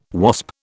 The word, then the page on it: Wasp: British English